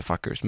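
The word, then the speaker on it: Fcukers: De har bytt plats på C-et för att man inte ska kunna anklaga dem för att de heter Fuckers. Men det är nog så man uttalar det ändå.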